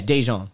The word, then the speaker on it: Daejeon: Yeah, Daejeon.